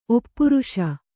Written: ಒಪ್ಪಿರಿಸು

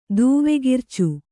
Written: ದೂವೆಗಿರ್ಚು

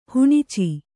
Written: ಹುಣಚಿ

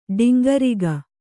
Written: ಡಂಗುರಿಗ

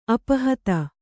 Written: ಅಪಹತ

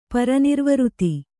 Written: ಪರಿನಿರ್ವೃತಿ

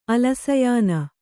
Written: ಅಲಸಯಾನ

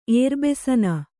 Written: ಏರ್ಬೆಸನ

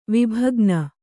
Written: ವಿಭಗ್ನ

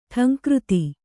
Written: ಢಂಕೃತಿ

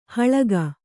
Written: ಹಾಲಿಗೆ

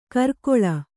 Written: ಕರ್ಕೊಳ

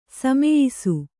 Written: ಸಮಯಿಸು

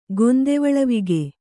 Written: ಗೊಂದೆವಳವಿಗ

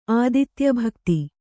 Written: ಆದಿತ್ಯಭಕ್ತ

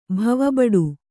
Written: ಭವಬಡು

